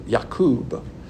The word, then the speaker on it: Yaqub: It's pronounced in the Hebrew, Yaqub.